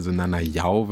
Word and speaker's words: Nanayao: Ja klar, also der Name Nanayao wird das ausgesprochen, wie Gabelyao. Kommt aus Ghana der Name, ja, das sind meine Wurzeln sozusagen.